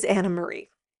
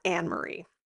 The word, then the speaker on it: Anna Marie: By the way, it is Anna Marie, not Anne Marie. So I just wanted to. So please don't DM me because that is how you say her name. I'm aware that it is spelt Anne Marie, but it is pronounced Anna Marie.